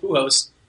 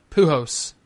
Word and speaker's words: Pujols: He did get Trout in there along with Pujols. And I agree. I liked Pujols against Verlander tonight. Wait, last night. – You saying it without an L? – Saying what? – Pujols. – Yeah. – Pujols.